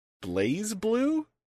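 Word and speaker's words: BlazBlue: I mispronounce this every time. Blaze Blue? – That's the correct pronunciation.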